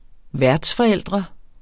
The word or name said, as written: værtsforældre